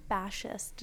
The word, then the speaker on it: Bashist: We are sitting here with Ira Bashist.